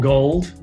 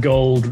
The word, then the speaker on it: Gauld: Well, it's Tom Gauld, and Gauld rhymes with called.